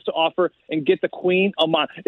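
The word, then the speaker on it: Kouign-amann: It's not spelled like king and queen. It's spelled with like a K and then like a Haman. It looks like Queen of Haman. And it's always funny hearing people like pronounce it and then they always correct you. Oh, it's Queen of Mons.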